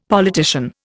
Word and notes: politician: Indian

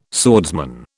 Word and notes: swordsman: British English

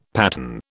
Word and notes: pattern: British English

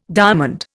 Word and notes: diamond: Indian

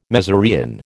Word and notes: mezereon: American English

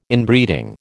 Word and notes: Inbreeding: American English

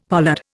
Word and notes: pollard: Indian

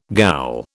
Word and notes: ghoul: British English

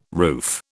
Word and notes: roof: British English

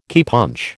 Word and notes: keypunch: American English

